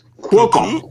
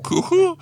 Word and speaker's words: Cuoco: For instance, Kaley Cuoco – I think that's how you say it. – Cuoco. – Cuoco.